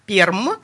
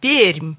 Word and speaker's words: Пермь: Так вот, друзья, первый раз он, конечно же, тоже произнес как Перм. А вот на второй раз пермяки все-таки научили его произносить название нашего города верно, и он уже сказал «Пермь».